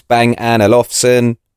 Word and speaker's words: Bang & Olufsen: The best way to remember how to say it is think of that Tiny Temper song where he says, Bang & Olufsen. – Okay, so you say it like that, Bang & Olufsen.